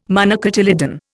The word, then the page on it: monocotyledon: Indian